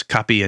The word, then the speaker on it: kapi: But there's Kumbakonam degree coffee, Mylapore filter coffee, and Madras kapi and kapi, which again is sort of supposed to sound phonetically like the word coffee.